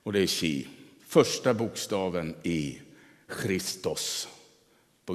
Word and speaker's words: chi: Vi brukar ju tala som XP i våra sammanhang. Och det är inte riktigt rätt. Vi uttalar dem på svenska. Chi och rå heter de på grekiska.